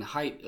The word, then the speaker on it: Haidt: And then she goes on to say, but as, uh, as atheist uh, social psychologist Jonathan Haidt observes, I assume I said his name correctly.